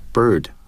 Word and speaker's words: bird: 所以这一点呢，我想 会为大家读一下 bird 和 hurt 这两个单词，因为它能更好的展示这两个单词的在美式英语的读音是怎么样。 – Bird, bird, hurt, hurt. – Some speakers of British English do not sound the R in this word.